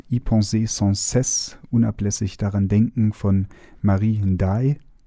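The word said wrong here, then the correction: NDiaye: Habe ich das richtig ausgesprochen? – Indiai. Indiai. Ja.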